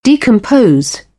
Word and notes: decompose: British English; əʊ

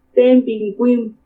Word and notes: Témbini ka kuimu: Purépecha

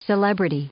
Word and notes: celebrity: noun

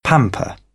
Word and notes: pamper: British English; əɹ

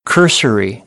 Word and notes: cursory: American English; ɝː

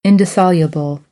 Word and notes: indissoluble: American English; ɑː

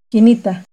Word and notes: Quinitta: Náhuatl

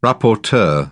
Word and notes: rapporteur: British English

